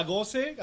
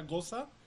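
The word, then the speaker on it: Gose: Sí, de hecho, la goce, así le estamos diciendo. Eh, hay gente que nos va a burlar de nosotros porque se pronuncia goza. – La goza. Pero pues se escribe goce.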